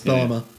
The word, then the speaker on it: Bellamy: How do you say it, Bellamy or Bellama? – Oh, is it Bellama? – Bellama. – Yeah, I think you're right. It's Bellama. – Which is a decent local band too.